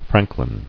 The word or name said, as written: Franklin